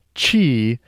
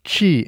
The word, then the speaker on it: qi: Today, I'm answering the question, what is this qi stuff? Qi is not pronounceable in English. It's a whistled aspiration that's not a sound that we have in our language.